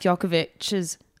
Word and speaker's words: Djokovic: In today's episode, we're discussing Novak Djokovic's, at first I don't know how to pronounce his name.